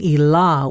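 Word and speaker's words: Ilā: and he became Ila with a long A, which designates a woman.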